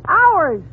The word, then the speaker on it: hours: H-O-R? Oh, that's easy. That's horse. – Fine. Now, how do you pronounce H-O-U-R-S? – H-O-U-R-S? Oh, Howers? – You pronounce it ours.